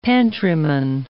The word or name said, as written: pantryman